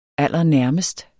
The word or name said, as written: stod